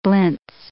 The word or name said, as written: blintz